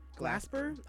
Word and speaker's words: Glasper: So for best R&B performance, we have Summer Too Hot by Chris Brown, Back to Love by Robert Glasner. Glasper. I probably say his name wrong. – Where you got the N from? – Huh? – Where you got an N from? – Okay.